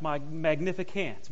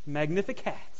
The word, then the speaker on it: Magnificat: Now, you might have heard it pronounced Magnificant, but it's not can't. It's cat. For all you cat lovers, that'll be easy to remember. Magnificat.